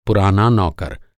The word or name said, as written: नौकर